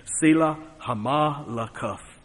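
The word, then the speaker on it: Sela-hammahlekoth: Therefore they call that place Selah Hamalakuth. Well, I give you the right pronunciation and the right interpretation, but I just call it Slippery Rock.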